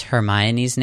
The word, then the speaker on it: Hermione: Do you remember how you first pronounced Hermione's name when you read it? – Hermione.